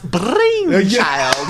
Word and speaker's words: Brainchild: It's pronounced Brainchild. – Thank you. – He rolls those R's.